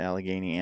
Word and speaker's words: Allegheny: For that, so people can go over there and get their hands on some of the Allegheny Ambush coffee. Did I say that right? Allegheny? – Yep.